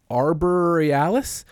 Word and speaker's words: Arborealis: It is arborealis, like the word arbor and then E A L I S.